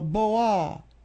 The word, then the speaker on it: Boal: B-O-A-L, Boal, Boal. – Yeah, that's the French.